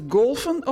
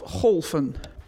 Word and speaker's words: golven: Is het golfen of golven? – Golven. – Daar is nog wel eens verwarring over. Er zijn veel vooroordelen over het golven.